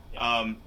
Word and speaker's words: Yeoh: Or they could have had, like, you know, Michelle Yeoh or, how do you pronounce her last name? Is it Yeoh? – Is it Yeoh? – Yeoh. Y-E-O-H.